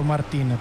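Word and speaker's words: Martín: Decidiamo, Martin o Martin? – Ma io credo che essendo spagnolo sarebbe più corretto Martin,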